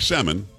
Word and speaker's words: Salmon: Sophie Salmon, spelled like salmon, but she says salmon.